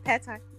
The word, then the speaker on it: pad thai: Did I say it right? – Yeah, pad thai.